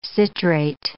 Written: citrate

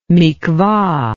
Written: mikvah

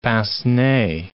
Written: pince-nez

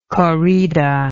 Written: corrida

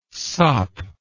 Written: sop

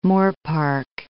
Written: Moorpark

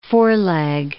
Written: foreleg

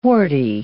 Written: warty

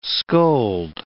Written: scold

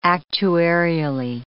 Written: actuarially

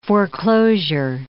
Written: foreclosure

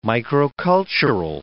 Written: microcultural

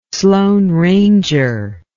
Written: sloane ranger

